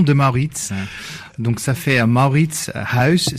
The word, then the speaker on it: Mauritshuis: Mais si on le prononçait à la néerlandaise, on le dirait comment? – Ça sonne comme Mauritshuis.